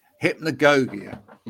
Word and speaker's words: Hypnagogia: Hypnagogia. – Hypnagogia.